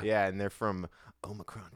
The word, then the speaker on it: Omicron: This was when that whole Omicron thing was, Omicron or Omicron? – It's Omicron. You're saying it right.